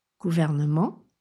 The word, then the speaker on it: gouvernement: Ne mangez pas la syllabe qui contient le E, donc pas de gouvernement, gouvernement. Et dans gouvernement, autre petit point de vigilance, le E dans la syllabe vert se prononce E, vert, contrairement à l'anglais où on a govern, ver, E, et nous on a E.